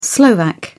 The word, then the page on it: slovak: British English